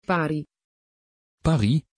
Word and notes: pari: Dutch

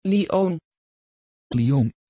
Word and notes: Lyon: Dutch

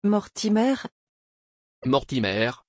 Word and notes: Mortimer: French

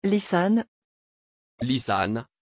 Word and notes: Lysanne: French

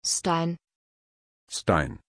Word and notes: Steijn: German

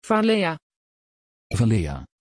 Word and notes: valea: Dutch

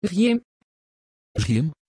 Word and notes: Zgjim: Dutch

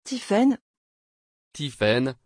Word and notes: tiphaine: French

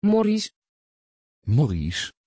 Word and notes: Morris: Dutch

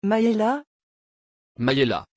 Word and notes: Mayella: French

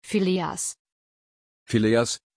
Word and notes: phileas: German

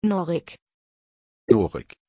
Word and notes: Norik: German

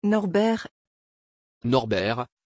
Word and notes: Norbert: French

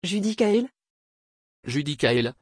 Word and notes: judicael: French

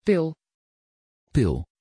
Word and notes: phill: Dutch

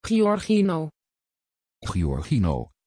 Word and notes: Giorgino: Dutch

